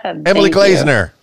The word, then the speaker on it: Emily Glazener: Emily Glazener.